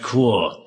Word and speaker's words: corps: I think it's pronounced corps. Corps.